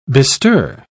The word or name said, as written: bestir